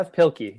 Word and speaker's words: Pilkey: Like, Dave, how do we pronounce his last name? Dev Pilkey, yeah.